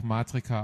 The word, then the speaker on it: Matrika: Wie wird das ausgesprochen? – Das wird ganz einfach Matrika ausgesprochen.